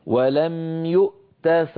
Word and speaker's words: ولم يؤت: فننطق همزة ساكنة ثم تاء شديدة ثم مهموسة ولم يؤت